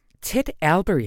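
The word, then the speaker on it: Ted Allbeury: Ted Albury, er det sådan, man udtaler hans navn? – Ja, det er det.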